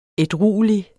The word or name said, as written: ædruelig